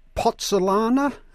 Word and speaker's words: pozzolana: Neocrete is made out of pozzolana, if I pronounce that right. What is pozzolana? – Very good pronunciation, actually. You could fit in well in Italy, where the name originated.